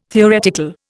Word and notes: theoretical: Indian

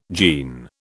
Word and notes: gene: British English

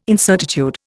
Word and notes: incertitude: Indian

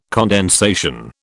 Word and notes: condensation: British English

